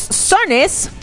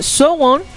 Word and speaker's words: SONE: Bueno, para todos los que somos fans de Girls' Generation sabemos que somos sones, que en coreano se pronuncia algo así como Sowon. No sone, como lo decimos acá en las Américas Latinas y en los Méxicos. No es sone. En Corea suena más como Sowon. Sowon es S-O-W-O-N.